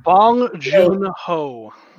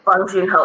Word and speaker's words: Bong Joon-ho: I decided over the past like few days to watch as many of, and I know I'm gonna pronounce his Bong Joon-ho. – Bong Joon-ho.